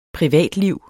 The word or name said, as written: privatliv